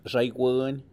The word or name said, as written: xaiguin